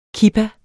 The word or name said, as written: kippa